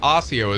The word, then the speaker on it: Osseo: You mean Osseo? – Osseo. – Osseo. – Osseo is the correct pronunciation.